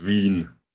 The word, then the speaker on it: Wien: What's the right way of pronouncing the city in the local language? – In local, it's Wien. – There we go, exactly. Yeah. It's but in English, it's Vienna, which is